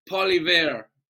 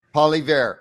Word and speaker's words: Poilievre: How do you pronounce his last name? The guy in Canada, Pierre Puvier or Pierre Poilievre. – Poilievre.